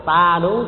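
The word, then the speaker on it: Sādhu: Chữ ớt này họ đọc là bằng chữ T. Cho nên sa đú á, đây mình đọc là sa đú đúng không? Mọi người miếng đọc là ta đú chứ không phải là sa đú nha.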